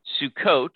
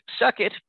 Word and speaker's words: Sukkot: Sometimes it is pronounced as suck it by those who do not know as well.